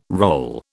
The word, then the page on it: roll: British English